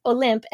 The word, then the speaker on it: Olympe: So it's Olympe, and I guess technically in French, they say Olympe.